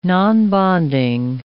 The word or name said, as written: nonbonding